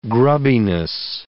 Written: grubbiness

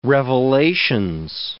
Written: Revelations

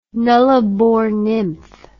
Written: Nullarbor nymph

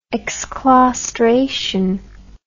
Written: exclaustration

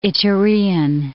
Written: Iturean

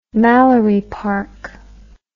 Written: Mallory Park

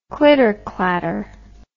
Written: clitterclatter

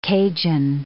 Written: Cajun

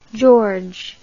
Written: Georges

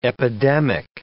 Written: epidemic

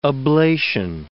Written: oblation

